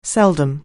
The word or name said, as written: seldom